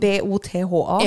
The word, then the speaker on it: Botha: Jag vet skriva B O T H A.